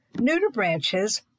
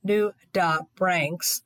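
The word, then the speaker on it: nudibranch: Nudibranches, pronounced various way, including nudibranchs, are a family of sea slugs.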